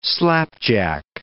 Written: slapjack

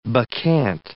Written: bacchante

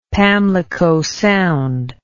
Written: pamlicosound

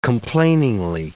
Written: complainingly

